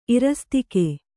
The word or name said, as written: ಇರಸ್ತಿಕೆ